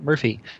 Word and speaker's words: Murfie: And Murphy, which is spelled M-U-R-F-I-E, is out of Madison, Wisconsin.